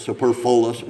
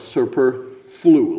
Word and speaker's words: superfluous: So that word, people pronounce it all different ways. Superfluous. No, it's superflu, like the flu.